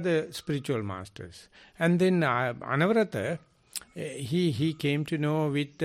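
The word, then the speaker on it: Anawrahta: And then when the king Anuruddha, Anavrata is the Burmese pronunciation, came to know this is not the Buddhism.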